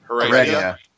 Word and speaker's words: Heredia: Pronounce his last name for me. – Heredia.